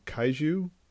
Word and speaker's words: kaiju: I know we've got our buddy Wilson Ramos, who's a member of works on Team Kaiju. Is that how you say it? – Yes, that's how you say it.